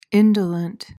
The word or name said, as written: indolent